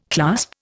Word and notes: clasp: Indian

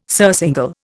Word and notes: surcingle: Indian

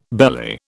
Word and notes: belly: British English

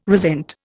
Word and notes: resent: Indian

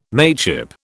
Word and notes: mateship: British English

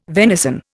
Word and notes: venison: Indian

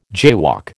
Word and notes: jaywalk: American English

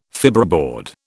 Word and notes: fibreboard: British English

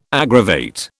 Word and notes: aggravate: British English

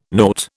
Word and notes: nought: British English

